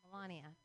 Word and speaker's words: Melania: It's Melania.